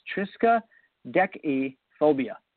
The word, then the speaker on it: triskaidekaphobia: So the first one is pronounced triskaidekaphobia.